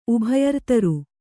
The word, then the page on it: ಉಭಯರ್ತರು: kannada